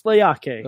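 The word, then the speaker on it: Leati: Looks like Leaky. Pronounced Laiaki.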